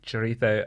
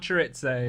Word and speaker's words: chorizo: Chorizo. It's the right way to pronounce it. It's not chorizo.